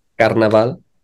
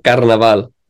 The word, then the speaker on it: Carnaval: Because we're going during a time called Carnival. It's like Mardi Gras in New Orleans, but Carnival, Carnival, how do you pronounce it? – Carnaval. – Yeah. Say it again. That was good, bro. – Carnaval.